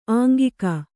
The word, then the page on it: ಅಂಗಿಗೆ: Kannada